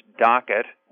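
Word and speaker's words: DOQ-IT: It's uh, DOQ uh, IT or pronounced DOCKET, which stands for Doctor's Office Quality IT.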